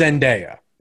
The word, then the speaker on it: Zendaya: I believe it's Zendaya. – Yes, I believe that's what I think it is.